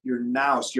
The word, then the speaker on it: nous: So the ancients would often talk about, and you read this in Orthodox theology, about the word N-O-U-S, like you can pronounce it nous.